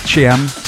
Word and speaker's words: Chiam: I think it's pronounced Chiam. C-H-I-A-M.